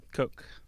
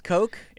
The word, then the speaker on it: Koch: I thought it was Koch brother. – Coke. – It's Coke? – It's K-O-C-H, right? – Yeah.